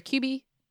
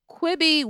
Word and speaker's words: Quibi: They write, "Was Kibby or Quibi? – How Quibi.